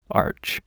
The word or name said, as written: arch